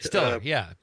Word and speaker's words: Stiller: Ben Stoller was was so nice. Did I get the name right? – Stiller, yeah. Ben Stiller, sure. – Stiller, I'm sorry.